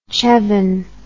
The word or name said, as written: chevin